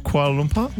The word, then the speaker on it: Kuala Lumpur: Is it, Sarah, is it Kuala Lumpur? – Yeah, you said it right. – Kuala Lumpur? – Yep.